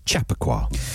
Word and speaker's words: Chappaqua: Chappaqua. – It's easy when you know how. It's like Jamiroquai.